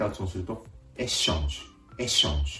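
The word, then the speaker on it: échange: てここも小さなポイントですけれども、シャ、シャ、エッシャンジュになるところですね。英語ではエクスチェンジ、シャの音でしたが、フランス語でエッシャンジュ、シャの音になる。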